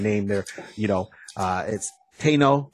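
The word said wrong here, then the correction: Teyonah: Oh my god! – I say it's Tiona. – Thank you. I apologize. – Tiona Paris.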